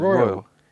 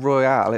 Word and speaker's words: Royle: Royal. – Royal. – Royal is the that's same for not, me. Because it's not Royale, it's Royal.